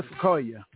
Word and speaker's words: Ifekoya: Miss Crystal Ifekoya, how you doing? – I'm doing wonderful.